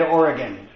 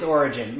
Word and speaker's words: Origen: Number one, I mispronounced the name. I referenced the early bishop, Origen. I had said Oregon, so it's not. It's Origen.